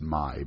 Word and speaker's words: mee: Mac, M-A-K, is Scottish for make. Mal, M-A-L, is an unpleasant degree. M-E-E, or me, are Malaysian noodles, or it is actually pronounced my, by the way, but spelled M-E-E. M-E-U is a plant, another name for a spignel. M-E-U, that's probably not even the right pronunciation of spignel, but M-E-U is a variation on that, and it's a plant.